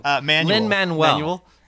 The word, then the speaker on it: Lin-Manuel: I mean, it's funny that we talk about this too, but I mean, when Lin-Manuel, is that how you pronounce it? – Lin-Manuel.